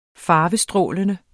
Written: farvestrålende

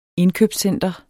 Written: indkøbscenter

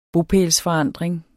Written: bopælsforandring

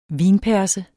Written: vinperse

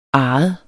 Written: arret

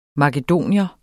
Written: makedoner

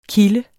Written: kilde

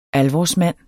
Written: ALVORSMANDENS

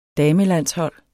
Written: damelandshold

